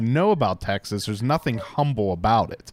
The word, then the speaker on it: Humble: It's pronounced Humble. – Humble. It's silent H.